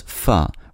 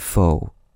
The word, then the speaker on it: pho: English speakers replaced the Vietnamese vowel with an O, but preserved the pronunciation as pho rather than pho.